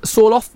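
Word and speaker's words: Sørloth: Sorloth, is that how you say his name? – Yeah.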